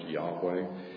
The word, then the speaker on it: Yahweh: The Hebrew version is, we would have four letters, YH, and I guess it'd be like a V or WH, and I'm not sure that we know exactly how that was pronounced, but it's commonly pronounced Yahweh.